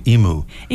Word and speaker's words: emu: But one of the famous birds in Australia that I hear mispronounced a lot in the U.S. is the emu. – Yeah, we call it, we just say emu. – Emu, yeah, right? It's not a cow. It doesn't moo.